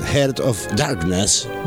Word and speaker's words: Heart of Darkness: Estamos hablando de El corazón de las tinieblas, Heart of Darkness. Uh-huh. ¿Dije bien, Casas? ¿Usted sí. que es inglesa? – Sí, sí, lo dijo bien.